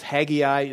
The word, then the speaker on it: Haggai: And maybe you've heard it pronounced Haggai. That's fine, too. Both pronunciations are correct. I'll go with Haggai.